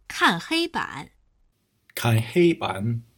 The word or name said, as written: kan heiban